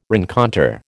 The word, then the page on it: rencontre: American English